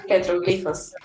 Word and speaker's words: petroglifos: Una flor silvestre que la niña Ashin estaba leyendo y según ella estaba leyendo esos petroglíficos, se dice así, petroglíficos, Sí. – Petroglifos. petroglifos, perdón. Esos petroglifos estaban haciendo la historia de cómo comenzó eh, el ciclo de los zombies.